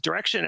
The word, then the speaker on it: Direxion: Just know that even though it's pronounced Direction, it's spelled D-I-R-E-X-I-O-N. So you might want to say Direction, but that's not how they pronounce it.